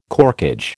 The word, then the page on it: corkage: American English